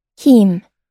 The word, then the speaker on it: heme: All right, I got to see how you pronounce it. – Heme. – Okay, so heme. It doesn't contain that. – Heme. – H-E-M-E, the genetically modified yeast.